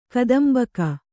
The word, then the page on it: ಗತಾಂಬಕ: gatāmbaka